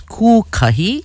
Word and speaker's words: Kūkahi: Today, Monday, October 13th, the Hawaiian moon phase is Kūkahi. That's spelled K-U with the kahako or the macron over the U. K-A-H-I, Kūkahi.